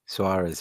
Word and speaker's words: Soares: Soares, is, is it? Cedric Suarez. Suarez, yeah. – Suarez. – Like Luis Suarez.